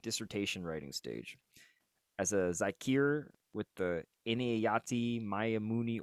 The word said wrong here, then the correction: Inayati Maimuni: Order, did I say that right? – Inayati Maimuni, close.